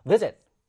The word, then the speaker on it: visit: 그러면 어디를 강하게 읽어줘야 될지를 결정하셔야 되고요. 이게 여러분들, 영어 단어의 강세예요. 그런데 이 단어 어떻게 읽죠? visit 또는 visit. 어떤 게 자연스럽지요? 그래요. visit.